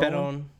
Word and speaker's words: Perón: Perón.